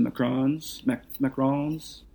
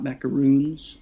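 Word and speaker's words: macaron: And then we made some macarons, not macaroons, as most people pronounce it wrong.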